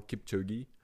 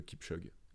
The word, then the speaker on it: Kipchoge: Ah ouais euh, je Kip- en, anglais, c'est très clairement Kipchoge. Que, que Ok. Et en France, euh, je pense qu'on dit plutôt Kipchoge.